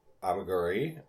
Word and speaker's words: amigurumi: So I did some research in the past week about amigurumi. I think we're pronouncing it wrong. I think there's one more syllable in there that we're missing. Amigurumi, something like that.